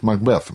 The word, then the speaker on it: Макбет: Да, потому что правильно говорит Макбет, Макбет на последнем слоге, потому что это на самом деле шотландская пьеса.